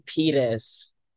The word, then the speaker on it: Pires: I don't know if is that actually how you say your last name? – It's actually Pyers.